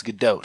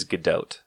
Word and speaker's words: Gadot: The way that I've heard her pronounce it is Gadot. – Okay, so Gal Gadot. – Gal Gadot. It's not like a hard T. – Okay. – It's like Gadot.